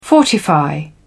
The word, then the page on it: fortify: British English